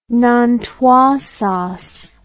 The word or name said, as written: nantua sauce